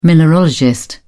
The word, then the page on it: mineralogist: British English